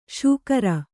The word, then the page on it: ಶುಕ್ರ: śukra; Kannada